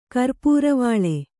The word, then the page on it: ಕರ್ಪುರವಾಳೆ: karpuravāḷe